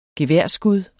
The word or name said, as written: geværskud